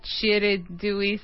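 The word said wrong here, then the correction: Chedraui: Select. – Cero. Muy mal. Muy mal. You are Russian. You should be able to pronounce the CH. Chedrawi. Chedrawi. – Muy bien. Chedrawi Select.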